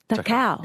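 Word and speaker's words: Cakau: His surname is spelt C-A-K-A-U. C-A-K-A-U, but the C is pronounced as a T-H sound. The cow.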